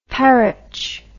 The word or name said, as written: parritch